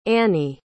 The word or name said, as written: annie